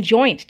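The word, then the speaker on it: Joynt: And joint too. I usually have to say joint, but with a Y.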